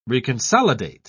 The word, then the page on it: reconsolidate: British English